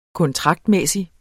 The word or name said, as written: kontraktmæssig